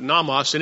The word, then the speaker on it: nomos: But nomos, and it means law. nomos is a better way to pronounce it.